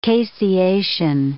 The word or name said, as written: caseation